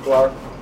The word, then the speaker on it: Clark: Detective Clark, Hartford Police Department. Last name is spelled C-L-A-R-K.